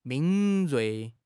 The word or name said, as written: 敏锐